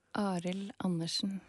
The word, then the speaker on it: Arild Andersen: And you mentioned Arild Andersen a moment ago. You said it better than I did. How do you say it again? – Arild Andersen. – Ah.